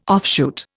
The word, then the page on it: offshoot: Indian